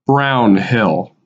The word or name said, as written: Brown Hill